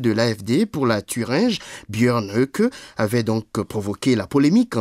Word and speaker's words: Björn Höcke: La revue de presse allemande à présent, Björn Höcke, c'est la bonne prononciation ? – Exact, Björn Höcke, oui.